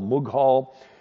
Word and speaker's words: Mughal: and they pronounce Mongol Mughal.